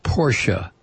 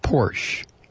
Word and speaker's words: Porsche: It's understandable for English speakers to make your mistake of not pronouncing the ending I in Schadenfreude because of the silent E at the end of so many of our words. But an E at the end of German words following a consonant is almost always pronounced a, as in Porsche, not Porsche.